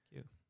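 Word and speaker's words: queue: Q-u-e-u-e. E. Ja. Hvordan uttales det? Q.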